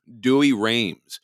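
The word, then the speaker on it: Douay-Rheims: Dewey Rheims. That's spelled D-O-U-A-Y hyphen R-H-E-I-M-S.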